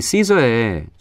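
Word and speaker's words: SZA: 참 참고로 저는 이제 이 시저의 초 음, 뭐예요 초기 활동에는 슈사라고 읽어가지고 어, 좀 스스로도 이게 맞나 싶어서 아예 찾아봤어요. How to pronounce 시저 이렇게 하니까 이제 나오더라고요 거기에 그래서 본인은 이걸 이제 시저라고 읽어달라 이렇게 이제 요구한 영상이 있어서 앞으로 저희 팝 스테이션에서는 무조건 SZA는 시저로 읽도록 하겠습니다 아티스트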